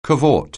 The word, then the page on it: cavort: anglais